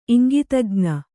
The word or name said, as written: ಇಂಗಿತಜ್ಞೆ